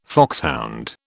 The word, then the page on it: foxhound: British English